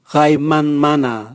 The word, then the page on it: gaimanmana: Raga